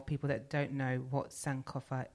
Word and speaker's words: Sankofa: If you don't know what Sankofa is, or Sankofa, I don't know if I'm pronouncing it right. – You are.